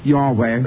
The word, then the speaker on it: Yahweh: But apparently, it is Y-H-W-H are the consonants, and pronounced like Yahweh, as far as we know.